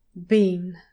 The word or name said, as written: bean